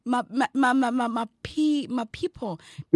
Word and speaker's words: Mapipo: Yeah, I'm sure you spelled it correctly, but pronunciation is Mapipo. – My people. – Yeah. – It's M-A... Yeah, like people. But yeah. – Okay. – M-A-P-I-P-O.